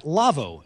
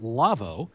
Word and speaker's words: LAWO: Our show is brought to you in part by the folks at LAVO, L-A-W-O, German company that makes audio consoles, pronounced LAVO.